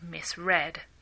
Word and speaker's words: misread: Notice that the past of to misread is spelt exactly the same, misread, but it's pronounced misread.